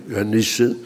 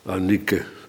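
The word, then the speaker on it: Eunice: In uw grootmoeder Lois en in uw moeder Eunike, want volgens de Nederlandse gebruiken moet je die C als een S uitspreken, Janisse. Maar het is Grieks, dus het is een... Je moet het uitspreken als een K.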